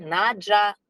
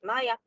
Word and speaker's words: Naja: Пишется как N-A-J-A, Наджа, Ная, по-разному произносится в разных странах. Будем для простоты произношения называть Ная.